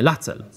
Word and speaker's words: Lacel: Także proszę i pastor Lecel, tak? La, nie, Lacel. Lacel tak się wymawia.